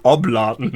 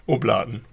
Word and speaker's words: Oblaten: Jetzt finde ich schön, dass du sie Oblaten nennst. – Was ist das? – Oblaten. Oder Oblaten. Betonung auf der zweiten Silbe.